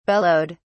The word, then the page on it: bellowed: British English